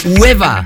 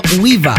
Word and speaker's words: Wehbba: Hablamos del brasileño Hueva, que no Uiva, se escribe W-E-H-B-B-A.